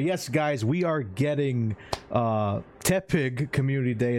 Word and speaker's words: Tepig: Tepig. – Tepig. – I always said T-Pig. I got T-pig.